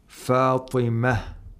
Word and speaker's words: Fatima: For example, the word Fatima is pronounced like ha. – Right. – But if we say Fatima tuz-Zahra, have to pronounce the ta.